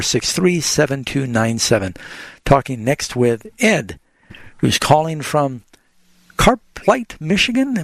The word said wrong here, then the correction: Carp Lake: Am I saying that right? Ed, you're on the air with Bible Answers Live. – Yeah. Carp Lake is a fish. – Oh, okay. – The fish, carp.